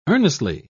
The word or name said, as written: earnestly